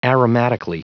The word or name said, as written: aromatically